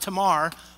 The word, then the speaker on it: Tamar: By the way, it's probably more accurately pronounced Tamar, but this Tamar is how I learned it in Sunday school, so you're going to have to deal.